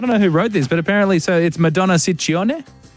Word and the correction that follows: Ciccone: Well, in Italian, it works the other way. So the double C's are hard C's and the singular C's are soft C's. – So Ciccone.